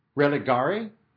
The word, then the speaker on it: religare: How would you say that? Religare. Religare.